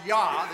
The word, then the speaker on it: Jah: And then the third part, which we pronounce Yah, the J-A-H, is actually an abbreviation for the very name of God. Sometimes you see the Jah, that is the J there, we pronounce it differently.